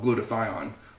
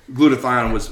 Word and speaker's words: glutathione: It's the CBD, it's the technology, and then we haven't even talked about the star of the show, and that's called glutathione, which I mispronounced for about 6 months. I called it glutathione, and I still think some of our corporate team still says it wrong, but glutathione is infused in the product.